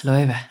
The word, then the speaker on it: Loewe: Men eh, jag var ju drog i, nu ska jag uttala namnet rätt här, Loeves showroom eh, i Paris. Loewe. Ja, Loewe.